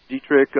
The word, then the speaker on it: Dietrich: If you're too young to remember who she is, it just put the word diet and rich together, D-I-E-T-R-I-C-H.